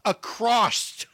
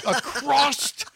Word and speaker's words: across: So many times I've wanted to jump in the middle of it and go, stop saying across. Across Wisconsin. And I thought you'd say it one more time, and now you say it right and make me feel like a fool. I was a fool to doubt you. You do know there's no T at the end of across.